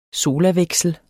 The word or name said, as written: solaveksel